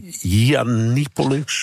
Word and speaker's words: Yiannopoulos: Ja, ik heb een beetje moeten lachen eigenlijk, dat is het beste woord, over die Britse ultra-rechtse influencer Mila Yiannopoulos, zo spreek je dat.